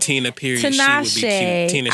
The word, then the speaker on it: Tinashe: I would put Pharrell and Tinashe.